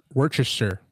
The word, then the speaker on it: Worcestershire: 1/2 cup of mayonnaise, 1/4 cup of ketchup, 1/2 teaspoon of garlic salt, 1/4 teaspoon of Worcestershire. Is that how you say it? Worcestershire? – Worcestershire.